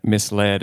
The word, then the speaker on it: misled: And we were together when she learned that misled is not pronounced misled.